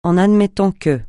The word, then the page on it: en admettant que: francais